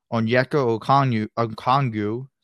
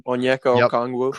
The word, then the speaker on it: Okongwu: Onyeka Okongwu. Sorry for not pronouncing. – Onyeka Okongwu.